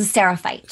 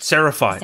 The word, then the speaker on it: Seraphite: So you were the is it Sephirite? Is that how you say it? – I was a Seraphite. – Seraphite, that's how you say it.